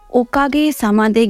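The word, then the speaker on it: ओ का गे सामा दे (おかげさまで): ओकागे सामादे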